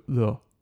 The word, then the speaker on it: l'eau: And yeah, in case you're wondering, because it is quite a, like the weirdest pronunciations in any language, the way it's spelled is L apostrophe E A U, right? So lo, right?